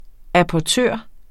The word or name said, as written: apportør